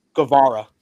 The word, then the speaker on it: Guevara: How do you say his last name? – Guevara. – Guevara. I'm sorry.